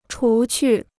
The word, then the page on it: 除去: chú qù